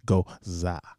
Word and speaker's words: Gose: pronounced G-O-Z-U-H, Gosa.